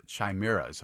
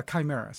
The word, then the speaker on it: chimeras: chimeras, if I'm saying that right. – Yeah, chimeras.